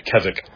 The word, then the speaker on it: Keswick: And by the way, Keswick, you know, this is a term that you all need to know. It's Keswick. It's not spelled how it sounds.